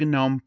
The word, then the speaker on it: GNOME: It is pronounced GNOME for a reason, because GNOME is an acronym.